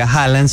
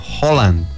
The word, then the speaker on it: Haaland: ¿Cómo les parece que esto es el dialecto o idioma noruego, y el apellido de él no se pronuncia Haaland, sino Holland? – Holland. – Uh-huh. Ah, porque tiene doble A. – Sí. – Y esa doble A, según por ahí estuve leyendo, al momento de unirse queda como una O.